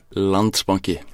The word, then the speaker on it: Landsbanki: Landsbanki. – Landsbanki.